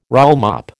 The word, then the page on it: rollmop: American English